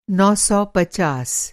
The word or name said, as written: नौ सौ पचास